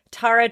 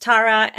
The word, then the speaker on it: Tara: During the break, Tara told me that her name again is pronounced Tara, and she likes it when I do it in my American way, Tara, because she says it makes her sound like a pirate.